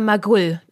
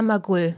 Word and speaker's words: Magull: Magull oder Magull? – Lina Magull, Mittelfeldspielerin Lina Magull. – Woher weißt du, dass das Magull betont wird? Weil ne? ich mir extra Interviews angeguckt habe, weil sonst wüsste ich ja überhaupt nicht, wer das ist.